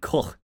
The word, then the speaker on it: Koch: Okay, so there are two ways to pronounce it. – Okay. – Which is Heckler and Koch, as you said. – Nice. – And Heckler und Koch, which is the German pronunciation.